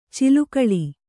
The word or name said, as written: ಚಿಲುಕೂಲಿ